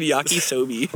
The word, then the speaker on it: yakisoba: Like old yakisoba. – What fucking yakisoba? – I think I said. I know. I mix like sobe the drink. I think I said yakisoba.